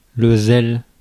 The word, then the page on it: zeal: /ziːl/